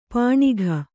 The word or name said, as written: ಪೆಣಕೆ